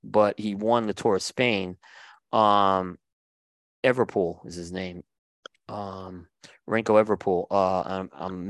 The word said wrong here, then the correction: Remco Evenepoel: Remco Evenepoel.